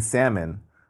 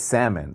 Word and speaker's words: salmon: And I think one time when we were dating, she was like, you know, it's salmon, right? I goes, what? It's salmon. You keep saying salmon. Like, you don't even have to pronounce the L.